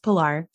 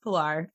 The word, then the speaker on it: Pilar: It's pronounced Pilar. – Pilar. Damn, but did I say Pilar? I did. – Yeah, it's fine. Everyone does it. I'll just tell you, it's pronounced Pilar.